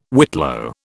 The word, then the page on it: whitlow: British English